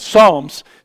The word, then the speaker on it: Psalms: Like Psalms starts with a P. You don't say Psalms, but you know, it's stuff like that, right?